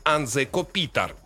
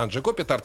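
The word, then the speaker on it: Anže Kopitar: Я вот один раз произнесу так, как, в принципе, правильно произносится и имя, и фамилия главной звезды Анзе Копитар. Так по-словенски произносится, но нам более привычно Анзе Копитар, так я его буду дальше называть.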